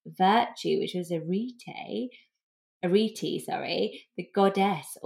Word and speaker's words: Arete: And this name is pronounced Arete, and it's spelt A-R-E-T-E.